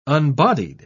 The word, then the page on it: unbodied: British English